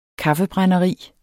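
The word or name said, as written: kaffebrænderi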